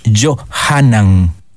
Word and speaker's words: Johanan: En castellano decimos el niño se llama Juan, pero en hebreo no se dice Juan. En hebreo el nombre es Johanan es un nombre casi en castellano es un nombre como femenino, pero en hebreo no.